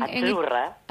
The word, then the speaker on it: azzurra: Azzurra. – Azzurra. Mira, ¿ves? – Azzurra. Sí, como porque pizza, la Z no es la Z como es, decimos en catalán tan sonora, no. Ah, es azzurra.